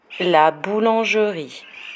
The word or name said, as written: la boulangerie